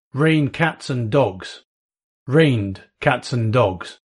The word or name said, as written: rain cats and dogs